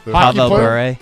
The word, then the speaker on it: Pavel Bure: Pavel Bure, yeah.